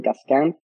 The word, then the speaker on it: Cascant: My name, Jordi Cascant.